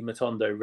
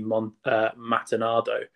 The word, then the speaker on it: Matondo: When I first saw the name Rabi Matondo written down, I completely mispronounced it and pronounced it Rabi Matinado.